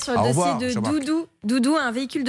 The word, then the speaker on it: dudu: Doudou, ça s'écrit D-U-D-U car c'est d'origine turque, mais ça se prononce Doudou.